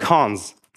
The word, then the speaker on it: cons: And this thing is pronounced cons.